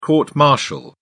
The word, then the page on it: court martial: British English